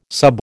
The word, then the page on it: sub: American English